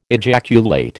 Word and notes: ejaculate: American English

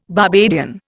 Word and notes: Barbadian: Indian

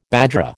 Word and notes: bajra: American English

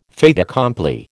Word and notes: fait accompli: American English

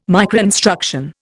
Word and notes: microinstruction: Indian

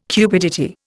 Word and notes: cupidity: Indian

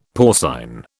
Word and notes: porcine: British English

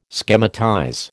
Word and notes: schematize: American English

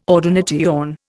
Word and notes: ordination: Indian

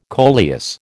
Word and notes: coleus: American English

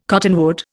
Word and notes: cottonwood: Indian

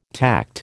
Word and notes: tact: American English

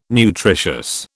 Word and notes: nutritious: British English

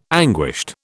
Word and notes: anguished: British English

